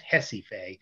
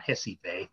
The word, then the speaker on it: Recife: And by the way, the pronunciation of Recife, R-E-C-I-F-E, is Recife. That's how the Portuguese pronounce it. They pronounce it with the H sound, Recife. it's a little tough sometimes. Some of the languages switch pronunciations and stuff, but it is Recife.